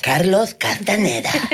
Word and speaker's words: Carlos Castaneda: Kan man få det korrekta uttalet? Carlos Castaneda.